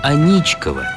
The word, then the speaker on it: Аничков: Строительством занимался инженерный батальон под командованием подполковника Михаила Степановича Аничкова, чей астраханский пехотный полк базировался неподалеку в старой финской деревне. Так, по имени своего создателя-офицера и получил мост название. Аничков с ударением на второй слог.